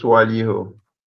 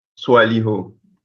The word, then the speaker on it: Swaliho: I am Swaliho. It is pronounced Swaliho. It is like it is spelled SWOLLOH. Swaliho. You pronounce Swaliho, Touré.